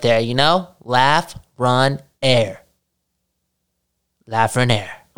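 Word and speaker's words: Lafrenière: Alexis Lafreniere. – Only three syllables, there you go. – Lafreniere. Oh, like you're laughing and you're running and you're gasping for some air.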